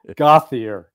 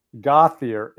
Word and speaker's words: Gauthier: It's actually Gautier. Gautier. And Gautier. When we're in when we're in France or someplace like that, I'm almost embarrassed to pronounce my last name.